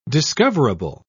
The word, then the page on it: discoverable: British English